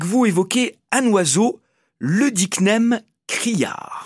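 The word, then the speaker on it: œdicnème: Le dicnème criard. – Voilà, celui-ci est quand même assez particulier, je trouve. Alors, dicnème, ça s'écrit O-E collé, et ça vient du grec œdéma, qui veut dire œdème.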